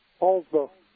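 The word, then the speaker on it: Poulsbo: Is it Poolsbo, Washington? – Poolsbo. – Poolsbo. Okay. – Yeah, just like the, what sounds like the Apostle Paul with a bow on it.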